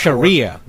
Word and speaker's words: Sharia: The two women were fined by the Shahara High Court. – Sharia. It's Sharia law.